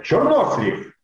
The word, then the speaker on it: чернослив: И говоришь «чернослив» или «чернослив». И говорит, нет, «чернослив».